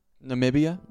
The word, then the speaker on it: Namibia: Take my mom to Nambia. – Namibia? – Oh, yeah. Not Nambia. Whoops. No, yeah, I pronounced that wrong.